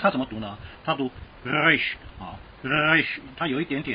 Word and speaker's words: resh: 我们在学希伯来文的这个 resh 啊，我们有特别说明过。但是呢，我们普通话没有小舌音，所以你很难发音，啊，所以你就把它读成卷舌音。啊，因为它大部分时间都是卷舌音啊，你读 ra resh 啊，大家都听得懂。